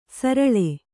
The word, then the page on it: ಸರಳೆ: kannada